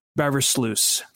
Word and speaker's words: Beversluis: So we're bringing it's a cat fight, so we're bringing in a Carolina Panthers guest, Brian Beversluis from Cat Scratch Reader.